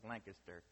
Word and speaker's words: Lancaster: And as you should know, it's Lancaster.